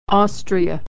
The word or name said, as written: Austria